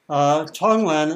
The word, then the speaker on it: Tonglen: Tonglen, it's spelled T-O-N-G-L-E-N.